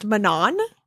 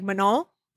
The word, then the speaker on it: Manon: Someone from Instagram commented on us saying that they laughed at the way we pronounced Manon, and because she's Manon, French and they don't say like the hard N. So it's like Manon.